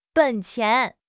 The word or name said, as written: 本钱